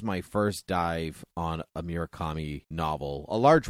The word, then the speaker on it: Murakami: And so did I say his name right? Yeah, Haruki. – Yeah, Murakami. Yeah, I did.